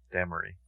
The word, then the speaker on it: Damrey: That's how you pronounce it, Damrey.